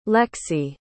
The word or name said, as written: lexie